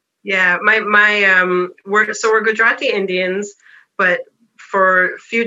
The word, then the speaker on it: Gujarati: Gujarati culture, and, you know, places like Dar es Salaam in Tanzania, where Rina, the main character, is from.